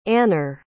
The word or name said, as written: anner